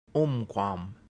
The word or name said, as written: umquam